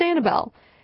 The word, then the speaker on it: Anabel: My name's Annabelle. It's spelled A-N-A-B-E-L, and I don't think I've ever seen a song that has it in that spelling.